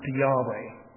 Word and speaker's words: Yahweh: And Moses is given the authority of the God who describes himself as YHWH, to use the English letters of the Hebrew, usually pronounced Yahweh by putting vowels in the middle.